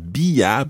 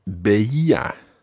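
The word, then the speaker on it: veía: But with ver, the irregularity is that the stem is VE. So it's not bia, but beia.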